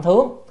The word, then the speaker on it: thướng: Rồi nhược linh thướng, chữ này là thướng chứ không phải thượng, chữ thượng nhưng mà đọc âm thướng. Thướng là lên, đi lên á, động từ, còn thượng là danh từ ở trên á, hoặc là giới từ ở trên, nhưng mà đây phải đọc là thướng.